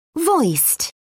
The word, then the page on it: voiced: British English